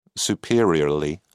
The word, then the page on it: superiorly: British English